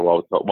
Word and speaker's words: Wauwatosa: Wauwatosa, Wisconsin. No, actually, I grew up in Milwaukee. – okay. I, I, did go to Wauwatosa.